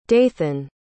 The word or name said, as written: dathan